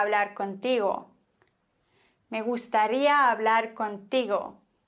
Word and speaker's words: contigo: Dieser Satz könnte so falsch ausgesprochen werden, falsch betont werden. Me gustaría hablar contigo. Ungefähr. Contigo.